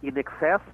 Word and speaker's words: INXS: Vou falar, essa banda, o nome é In Excess, muita gente no Brasil chamava de In Excess, mas na verdade o INXS era para fazer um jogo com a pronúncia, com a expressão em excesso, né?